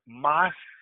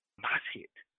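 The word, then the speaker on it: mashchit: It's pronounced Moshit. Moshit.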